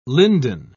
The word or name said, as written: linden